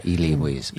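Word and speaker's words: Elie Wiesel: Knight, Eli Wiesel. Am I saying that right? – Yeah, Eli Wiesel.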